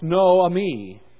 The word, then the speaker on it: Naomi: Transliterated, her name simply would be pronounced Noami.